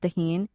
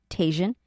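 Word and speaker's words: Tajín: Tajin It's pronounced Tajin, or in English, Tajin.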